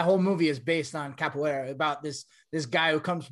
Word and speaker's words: capoeira: I'm saying it wrong, but it's capoeira. – Badass. – It's capoeira.